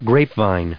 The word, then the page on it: grapevine: grape*vine; American English